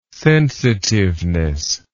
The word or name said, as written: sensitiveness